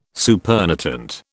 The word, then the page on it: supernatant: British English